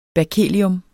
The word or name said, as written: berkelium